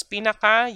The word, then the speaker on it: pinaka: Superlative form is, if you want to use pinaka, you say pinakatangkad si Jonathan. Pinakatangkad si Jonathan. Notice the stress on pinaka. Pinaka. That's how you pronounce it.